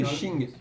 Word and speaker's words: Qing: Au profit du c'est Qing. – C'est ça, le Q se prononce, ouais, c'est ça.